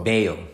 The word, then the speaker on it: Bello: So, like, the factory we went to yesterday, that was Bello or Balo? – Bayo. – Bayo. B-E-L-L-O, right? – Yep. – Yeah, but the way they pronounce it is yeah. – Bayo. – Two L's in it. Two L's in Spanish is what a Y.